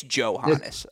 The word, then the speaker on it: Johannes: By the way, we both said Johannes the first 2 years. It's Johannes. It's a hard J.